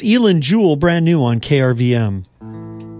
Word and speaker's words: Eilen: Elin Jewell, that's spelled E-I-L-E-N, Elin.